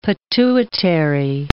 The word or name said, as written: pituitary